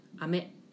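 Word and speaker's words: 飴: Please tell me, what's the pronunciation of rain? – Ame. – Ame. And candy? – Ame.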